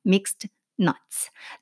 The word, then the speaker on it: mixed nuts: mixed nuts. 자, 영어 발음을 조금 제가 정확히 하려고 노력하고 있습니다. 왜냐면 하 우리 한국 사람들은 믹스 넛믹스 그러니까 nuts에서 plural plural 복수형인데 s 빼고 nut 만 쓰기도 하고 혹은 요즘에는 한글에서 너츠까지도 쓰기도 하는 것 같아요.